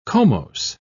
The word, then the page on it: comose: British English